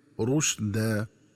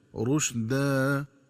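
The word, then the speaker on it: رُشْدًا: وكذلك ينبغي التنبه للنطق الصحيح لكلمة رشدا، فتقرأ بضم الراء وتسكين الشين هكذا رشدا، ومن الخطأ قراءتها بفتح الراء والشين هكذا رشد.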